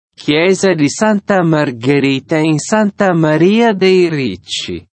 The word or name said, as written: Chiesa di Santa Margherita in Santa Maria dei Ricci